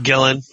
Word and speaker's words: Gillan: Karen Gillan's in Gillan. – It. Gillan.